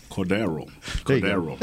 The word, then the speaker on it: Cordarrelle: C-O-R-D-A-R-R-E-L-L-E Patterson. He's a do-all type guy. – How do you pronounce it? How you say it, though? – Cordarrelle. – Cordero. – There you go.